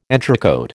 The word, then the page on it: entrecôte: American English